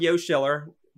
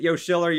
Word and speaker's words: Yoshiller: Uh, oh, my YouTube channel is called Yoshiller, Y O S H I L L E R, not Yoshi Killer, not Yosh Killer. Uh, how how you pronounce it? Yoshiller, Yo Schiller.